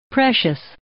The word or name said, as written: precious